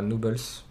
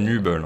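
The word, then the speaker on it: Nübel: Nouble dans les buts et non pas Nubles. Voilà, C'est même Nubel, hein, si tu veux faire. – Nubel, pardon, pour un petit message au commentateur qui rajoute des lettres sans raison au moment de prononcer les noms des joueurs.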